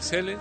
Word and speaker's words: Zelle: Zelle, se pronuncia Zelle.